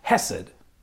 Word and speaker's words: hesed: And the key word in that sentence, the word that's translated here as love, in Hebrew that word is pronounced hesed, which refers, our language in English is just too limited.